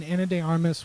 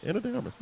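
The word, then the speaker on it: Ana de Armas: Yeah, I loved Knives Out, and Anna de Armas was fantastic. – I did say that correctly, didn't I? – Yes. – Anna de Armas, nice.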